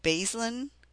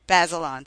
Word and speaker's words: Bazelon: You have a, I'm not sure how you pronounce it, Bazelon. – Bazelon, thank you.